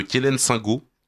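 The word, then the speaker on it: Quelaines-Saint-Gault: Et on a pu voir ça en Mayenne, dans le charmant village de, vous me direz si je prononce bien, de Kélène-Singo. – Oui, c'est ça.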